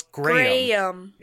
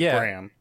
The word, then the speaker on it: Graham: Well, it's Graham. It's a two-syllable word in... The UK. Oh. – Graham? – Us dumb Americans say Graham.